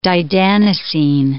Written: didanosine

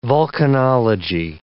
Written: vulcanology

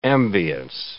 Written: ambiance

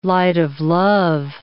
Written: light-of-love

